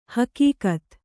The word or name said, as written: ಹೆಗ್ಗತ್ತು